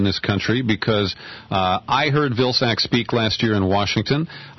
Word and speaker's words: Vilsack: Vilsack, mm-hmm.